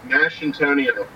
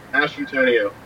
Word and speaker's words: Masciantonio: It's Masciantonio. It's like mashed potatoes. Masciantonio. – Mash Antonio. Okay. Okay. – Yeah, Mash Antonio.